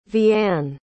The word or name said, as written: vianne